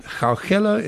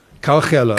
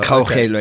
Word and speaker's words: Kgaugelo: And I, have never asked you the question: Is it Gaugelo? Is it Kauchelo. How do you pronounce it? Or can I just call you KG? It's Kgaugelo. – Kgaugelo. Kgaugelo, okay.